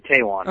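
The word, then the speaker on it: Taijuan: Also, James Paxton and Taiwan Walker. Which do you say? – I pronounce it Taiwan. – Okay, that's very well be Taiwan.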